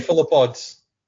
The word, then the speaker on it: cephalopods: Cephalods? No, now I've confused myself. – Cephalopods.